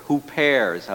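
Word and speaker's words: hupers (ὑπέρ): The Greek word, the original language, is huper, hupers, as it's pronounced.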